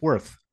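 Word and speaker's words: Wraith: They're the W-R-A-R-T-H Warriors. The Worth Warriors. They're the Worth Warriors. – They're the Worth, for what it's worth.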